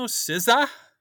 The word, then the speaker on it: SZA: And she spells her name S-Z-A, but she pronounces it SZA.